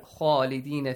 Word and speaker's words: خالدين: So, the first important rule here, خالدين فيها, the pronunciation of the letter خا is important since it's a heavy letter and it has an alif mad with it. So you gotta pronounce it with an ah sound.